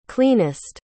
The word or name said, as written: cleanest